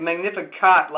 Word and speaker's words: Magnificat: It's like Magnificat, like you sleep on a cot.